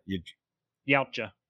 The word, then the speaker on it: Yautja: But two, okay, so two I like because two expands so much more on the lore of I'm going to fuck it up. Yajuda? – Yautja. – Yautja? That's how you say it? – Mm-hmm. – Way off. Okay.